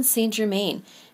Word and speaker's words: Saint-Germain: If you want to practice forgiveness and transmutation, call on Saint Germain. Saint Germain, well, he's actually not a saint. If you are pronouncing it the French way, it is Saint-Germain.